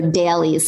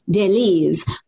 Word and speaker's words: Daleez: It's called dailies, but it's spelled D-A-L-E-E-Z. So dailies. Sometimes I Frenchify it and I say dailies, but why not? – It's dailies.